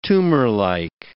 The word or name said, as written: tumorlike